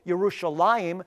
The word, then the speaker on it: Yerushalayim: as I indicated is in the case with Yerushalayim, which is the correct way to say Jerusalem, versus Jerusalem.